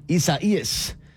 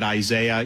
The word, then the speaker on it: Isaias: And I'm sure she's going to be keeping an eye just like we are and just like a lot of people are on Hurricane Isaias. First of all, that is how you say it.